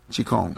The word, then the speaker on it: Qigong: Qi, Qigong, Q-U-O-N-G, but it's Qi. Qigong, Qigong.